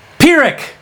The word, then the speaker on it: Pyrrhic: Pyrrhic! Ha ha! Not an F sound, it's a P sound. Pyrrhic. Pyrrhic. Pyrrhic with the P.